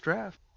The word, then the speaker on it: draught: It's the drought stout. – That's draft. – No, it says draught. It doesn't say draft. – I believe that's pronounced draft.